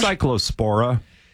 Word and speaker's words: Cyclospora: Cyclospora.